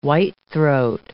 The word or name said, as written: whitethroat